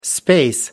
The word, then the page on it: space: American English